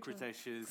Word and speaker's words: Cretaceous: Uh, I'm, I'm not very good at pronouncing crit- Cretaceous.